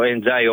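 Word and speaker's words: Ndiaye: Τώρα ο Ενζάι, όπως έχει πει ότι το λένε, ότι προφέρεται το επίθετό του.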